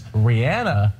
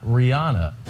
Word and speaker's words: Rihanna: She wants everyone to know it's Rihanna, not Rihanna.